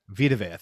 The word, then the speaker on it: Vita Vea: Vanti Vateo. What's his name? I don't know how you say it. Jason Pierre-Paul. – Vita Vea.